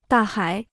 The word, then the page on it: 大海: dà hǎi